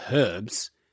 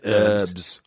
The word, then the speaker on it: herbs: But Eddie Izzard says this thing, which is true, that like we pronounce it herbs, and you guys pronounce it herbs. Because there's a fucking H at the start of the sentence.